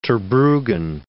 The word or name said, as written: Terbrugghen